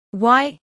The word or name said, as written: y